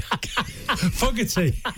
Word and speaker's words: Fogarty: Fogarty.